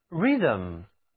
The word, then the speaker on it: rhythm: 首先，音乐是符合韵律的声响艺术，韵律是 rhythm， rhythm，字母 th 组合起来读作 z，这个单词的重音放在一开始，韵律、格律、节律， rhythm，就像我们诵读诗歌也需要按照一定的节律，符合音乐的节拍， rhythm。